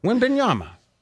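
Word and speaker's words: Wembanyama: Wimbenyama.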